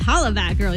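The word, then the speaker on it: Hollaback Girl: Yeah, it's Holler Back Girl. You gotta say it right.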